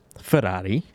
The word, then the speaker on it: Ferrari: So that is Ferrari, because you have to say it like that.